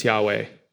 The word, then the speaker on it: Yahweh: When you see it written like that in your Bible, what that means is that it's the divine name which we pronounce Yahweh.